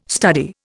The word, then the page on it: study: Indian